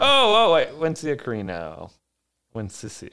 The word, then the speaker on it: Wensicia: No doubt to the outrage of the Princess Wensicia. – Wensia. – Wensia? – Yeah. I have always read that as Wensia. – Yeah, it's Wensicia.